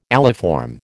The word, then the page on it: aliform: American English